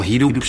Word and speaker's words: Hidup: Hoe spreek je het nou uit? Hidop? – Hidup, zeg maar, dat is denk ik officieel.